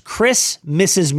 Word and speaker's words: Chris, Mrs.: Now, I do want to make sure I'm saying it properly. It's Christmas's Musical. – Christmas's a new holiday musical.